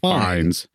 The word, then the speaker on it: Fiennes: And it's spelled Fiennes. Yeah. But it's pronounced Fiennes.